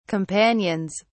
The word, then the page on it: companions: British English